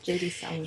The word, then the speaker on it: J.D. Salinger: I love this quote by J.D. Salinger. Is that how you say it? Catcher in the Rye author? – Yeah. – J.D. Salinger.